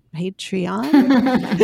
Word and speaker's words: Patreon: I don't even know how to say it. Patreon. – You're saying it right.